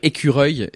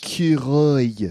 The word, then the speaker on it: écureuil: Like, écureuil is really difficult for English people to pronounce. – Écureuil.